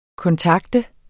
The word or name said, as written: kontakte